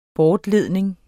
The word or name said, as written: bortledning